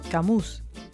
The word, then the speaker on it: Camus: ¿Cómo se dice? Camus.